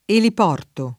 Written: eliporto

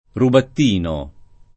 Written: Rubattino

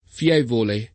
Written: fievole

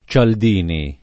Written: Cialdini

